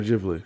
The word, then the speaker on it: Ghibli: And like the drawings and illustrations of these foods are like Ghibli. Why do I feel like I'm saying that wrong? – Studio Ghibli.